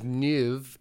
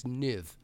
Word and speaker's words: Villeneuve: um, I will say in my defense that it's because "nuv" is the way that you would pronounce Nuve in New Zealand. So Nouvelle Zealand in, in French, but I was overemphasizing the "nuv" and it's "niv."